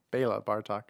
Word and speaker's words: Béla Bartók: Béla Bartók, as I think is correctly pronounced.